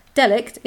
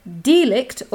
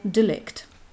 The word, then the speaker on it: delict: It's not delict, it's either delict or delict. – According to the... D-E-L-I-C-T. And remind us all again what that means. – It's tort and delict, which is a branch of law.